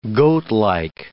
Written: goatlike

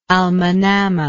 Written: al manamah

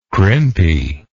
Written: crimpily

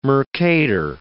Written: Mercator

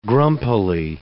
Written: grumpily

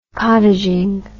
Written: cottaging

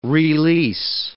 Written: re-lease

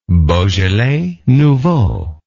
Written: beaujolais nouveau

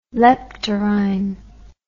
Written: leptorrhine

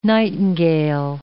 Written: nightingale

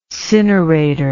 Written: cinerator

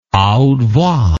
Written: a haute voix

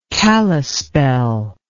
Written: kalispel

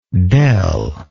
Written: del

